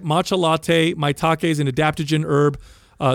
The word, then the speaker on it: Maitake: First off, we mentioned four sigmatics mushroom matcha latte with, how do you say it, Doug? – Maitake. – Maitake.